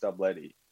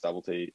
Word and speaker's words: Stubblety: So it is pronounced Stubletty, but like everyone pronounces Stubblety at the Olympics.